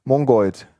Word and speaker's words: Mongoid: Mongoid.